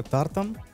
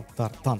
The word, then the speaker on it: тартан: То есть эта клетка — это тартан. Именно с ударением на тартан. То есть на А, на вторую Тар.